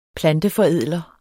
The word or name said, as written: planteforædler